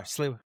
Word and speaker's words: Sliwa: Curtis Sliwa, and I'm not even sure if I'm saying his name right. – You are, Sliwa.